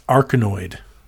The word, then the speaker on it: Arkanoid: That's Arkanoid. I presume I'm pronouncing that correctly. – That's how I would say it.